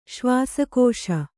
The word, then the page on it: ಶ್ವಾಸಕೋಶ: kannada